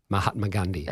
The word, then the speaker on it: Mahatma Gandhi: How do you say his name? – Mahatma Gandhi. – That's it.